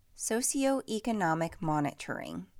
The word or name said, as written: Socio-economic monitoring